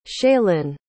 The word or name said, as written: shaylynn